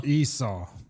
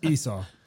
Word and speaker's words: Esau: How do you say, like, Jacob and Esau? – Esau. – Yeah. – That's the one that I always want to call it Esau. – Esau? – it's Esau.